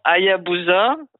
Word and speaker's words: Hayabusa: attention à la prononciation, Hayabusa, sur l'astéroïde Ryugu.